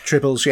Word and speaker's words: Tribbles: Tribbles, yeah.